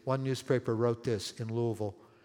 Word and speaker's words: Louisville: And it spread up across to Buffalo, over to Chicago, across to Newark, New Jersey, over to Louisville, Kentucky. Louisville, I had to learn how to pronounce that word. It's not Louisville. It's Louisville. Louisville.